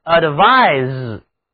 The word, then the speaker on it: advise: 请注意，动词建议读音是 advise。 se 组合起来读浊辅音 z。